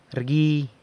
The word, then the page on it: rgui: sanlucasquiavini